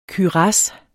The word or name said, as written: KYRASSERNE